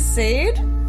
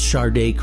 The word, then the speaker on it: Sade: You actually listen to Sade? You own a Sade cassette? – It's pronounced Sade. Don't just look at the cassette.